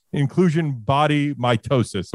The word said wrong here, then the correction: myositis: Am I saying it right? – Myositis. – Myositis. I apologize.